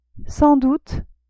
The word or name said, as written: sans doute